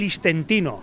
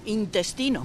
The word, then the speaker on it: intestino: ¿Cómo se dice? ¿Intestino o istentino? – No, intestino.